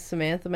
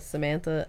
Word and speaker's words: Samantha: All right, so my name is Samantha Magdaleno, Samantha, S-A-M-A-N-T-H-A, Magdaleno, M-A-G-D-A-L-E-N-O, running for state representative in District 6.